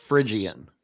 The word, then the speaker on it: Phrygian: For Phryian, Phrygian. – I think it's pronounced Phrygian. – Phrygian. And there we go.